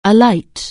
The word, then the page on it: alight: English